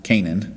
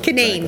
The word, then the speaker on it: Canaan: So it's not the biblical. It's spelled the biblical. – Spelled right? Like the biblical, which was you typically hear pronounced Canaan. – Yeah. – But it's the local. Everyone here calls it Canaan.